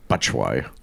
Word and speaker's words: Batshuayi: So with Abraham out, they played Batshuayi, which I know I'm pronouncing it correctly, good.